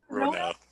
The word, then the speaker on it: Rona: So something that I would like to mention, I'm sure Alex and um, Rona, Rona, is that how you say your name? – Rona.